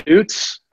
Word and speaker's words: Oots: Yeah, the best way is going to be Instagram and Facebook at the Scott Oots.